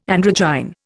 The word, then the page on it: androgyne: Indian